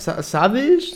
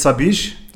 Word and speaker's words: Sabich: Wir, sag jetzt, wir sagen ab jetzt Sabich. Ja. Das wird so nicht ausgesprochen.